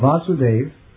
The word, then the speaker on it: Vasudev: The platform is called Vasudev with a long A, or Vishuddha Sattva.